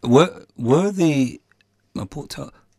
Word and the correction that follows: Mapuche: can I pronounce it? – Mapuche. – Mapuche. Were the Mapuche...